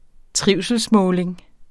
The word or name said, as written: trivselsmåling